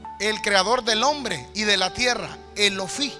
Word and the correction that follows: Olofi: Yo no sé si lo estoy pronunciando bien, perdón, Olofi, Olofi, así se llama.